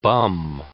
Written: bum

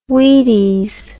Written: wheaties

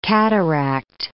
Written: cataract

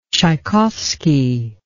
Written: tschaikovsky

